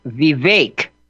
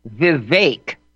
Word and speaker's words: Vivek: The correct pronunciation of his first name is Vivek. Vivek, accent on the second syllable, Vake. Vivek, and the second last name, Ramaswamy. The accented syllable is the swa, S-W-A-H, Ramaswamy.